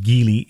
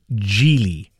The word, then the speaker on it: Geely: Some people call it Geely. It's not called Geely. It's called Geely. Yep, it's called Geely. It's spelled G-E-E-L-Y, right?